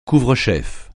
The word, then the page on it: couvre-chef: French